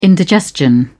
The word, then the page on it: indigestion: British English